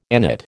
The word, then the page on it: agnate: American English